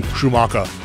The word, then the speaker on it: Schumacher: I'm trying to get the real pronunciation here. – I'm going German. – Schumacher.